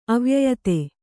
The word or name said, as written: ಅವ್ಯಯತೆ